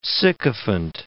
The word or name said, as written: sycophant